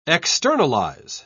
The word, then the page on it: externalise: British English